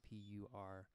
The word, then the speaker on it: Perfumery: And if you want to check out some songs that are out by myself, it's under the artist name Perfumery. Spelled like the place you make perfume, Perfumery.